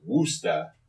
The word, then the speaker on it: Worcester: What is spelled as Worcester is Woosta, if you are, Cockney? – Bostonian.